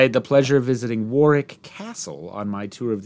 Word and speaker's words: Warwick: So Warwick is always pronounced with a silent second W, even Warwick Davis.